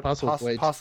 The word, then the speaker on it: Postlethwaite: Yeah, Postlethwaite.